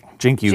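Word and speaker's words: Cenk Uygur: say his name for me, please. – Jink. Jink Uger. – Jink Uger. Thank you.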